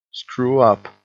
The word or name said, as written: screw-up